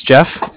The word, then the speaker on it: Jeff: My name's Jeff, J-E-F-F, and I'm calling from the Bank of Cardiff. Cardiff is spelled C-A-R-D-I-F-F, as in Frank Frank.